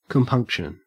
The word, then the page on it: compunction: Australian English